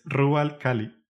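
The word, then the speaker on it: Rub al Khali: Ah, bueno, digamos en su idioma, en árabe supongo, es Rubal Cali. – Ok. – Eh, que digamos, en el juego lo pronuncian como Rubal Cali, así más rápido.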